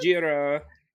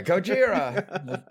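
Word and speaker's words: Gojira (ゴジラ): How'd you pronounce it? – Gojira. – Yeah, Gojira.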